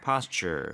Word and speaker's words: posture: Posture. – 네, 중간에 T 발음을 약간 CH 발음으로 한번 해주세요.